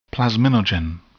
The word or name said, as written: plasminogen